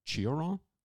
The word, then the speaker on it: Cioran: Cioran? – Cioran, we say in French.